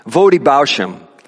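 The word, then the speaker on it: Voddie Baucham: Vodi Bausham. I don't know how many know him. That's the way I think it's the way his name is pronounced. V-O-D-D-I-E. Bausham. B-A-U-C-H-A-M.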